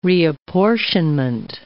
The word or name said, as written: reapportionment